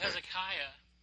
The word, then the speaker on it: Hezekiah: Before we can talk about Hezekiah, we need to learn how to pronounce it, right? Say Hezekiah. No, it's Hezekiah. Like a cat with a hairball back in the throat.